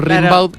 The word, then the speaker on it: Rimbaud: Claro, se escribe, se pronuncia Rimbaud, pero Rimbaud.